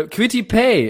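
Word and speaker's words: Kwity Paye: Äh, Quitty Pay, äh, der tatsächlich so ausgesprochen wird,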